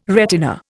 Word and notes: retina: Indian

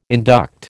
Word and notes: induct: American English

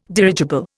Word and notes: dirigible: Indian

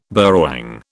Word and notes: burrawang: British English

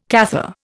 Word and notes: Cathar: Indian

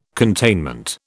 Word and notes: containment: British English

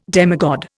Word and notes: demigod: Indian

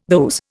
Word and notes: those: Indian

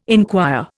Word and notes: inquire: Indian

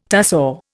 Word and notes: tussore: Indian

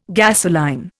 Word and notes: gasoline: Indian

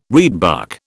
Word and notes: reedbuck: British English

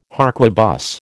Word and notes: harquebus: American English

